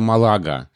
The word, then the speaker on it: Малага: Да, потому что слова времен собственных русского языка Флоренции Леонида Нагенко э, а, дает ударение «Андалусия», и название города Малага.